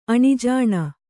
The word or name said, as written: ಅಣಿಜಾಣೆ